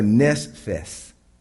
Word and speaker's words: nephesh: It's pronounced, and we went over this before, nephesh.